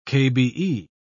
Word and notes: kbe: British English

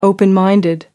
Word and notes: open minded: American English

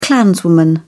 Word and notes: clanswoman: British English

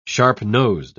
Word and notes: sharp-nosed: British English

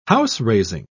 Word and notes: house-raising: British English

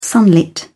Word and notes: sunlit: British English

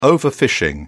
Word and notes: overfishing: British English

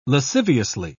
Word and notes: lasciviously: British English

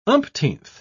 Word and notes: umpteenth: British English

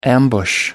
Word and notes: ambush: American English

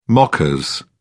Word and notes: mockers: British English

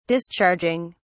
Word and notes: discharging: British English

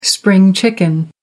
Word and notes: spring chicken: American English

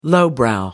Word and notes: lowbrow: American English

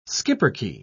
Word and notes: schipperke: British English

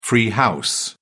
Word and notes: free house: British English

